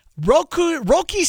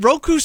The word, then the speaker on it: Roki: Roki Sasaki. Have I got the name right? Roku Sasaki. – Well, I think he had it closer the first time.